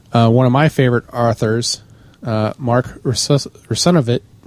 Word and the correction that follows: Russinovich: That almost came out completely wrong. How do you say that, Russinovich? – Yes, thank you.